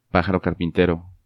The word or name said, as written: Pajaro Carpintero